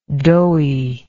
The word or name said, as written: doughiness